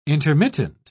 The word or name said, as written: intermittent